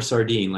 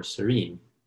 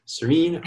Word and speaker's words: Sorinne: Do not call her Sardine like I did. Call her Sorinne. Sorinne Ardeleanu.